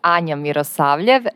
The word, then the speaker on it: Anja Mirosavljev: So my name is Anya Miosaviev, and shout out to you too, because you did a great, great job.